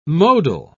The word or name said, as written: modal